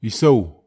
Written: isau

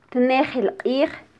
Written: tnejil qij

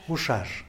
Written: guxax

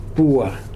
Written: pua

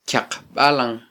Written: kyaqbalan

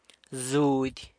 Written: zudy

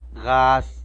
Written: gaz

